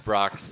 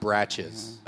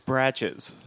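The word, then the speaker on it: Brach's: Brock's the candy, the caramel candies. Brock's. – It's pronounced Bratches. – Yeah. Oh. – Yeah. That's a... – But it's spelled the same as Brock's. – It's Bratches.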